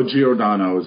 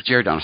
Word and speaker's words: Giordano: Oh, Giordano is how it's pronounced. – Giordano, sorry, yeah.